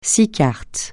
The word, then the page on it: six: francais